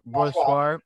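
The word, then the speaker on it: Brossoit: Laurent Brossoir, sorry for the mispronunciation. – Brossoir. – Yeah, Brossoir.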